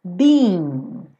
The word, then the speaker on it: being: Being. Tem que ter esse som, being, being, tá? Porque senão fica be ou been, né? Então, being. São como duas sílabas. Being, being, foolish.